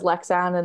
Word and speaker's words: Leksand: Is it Leksands? Is that the best way to pronounce it? – Yeah, the town is Leksand, and then the club is Leksands EF. That's like the club.